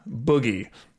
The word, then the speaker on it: Booji: Right, that is B-O-O-J-I, Boogie. – Yeah, and what's the reason for that pronunciation and spelling discrepancy?